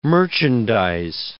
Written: merchandize

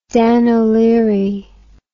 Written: Dan O'Leary

